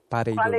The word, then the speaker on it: Pareidolia: Pareidolia. Qual è, qual è la verifica? Cosa vuoi sapere? – Beh, se l'accento era corretto. – perfetto, sì, sì.